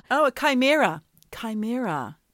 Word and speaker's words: chimera: I did not know how to say it because it's spelled C-H-I-M-E-R-A. – That's right. – I thought it was a shimmera or a chimera. Chimera.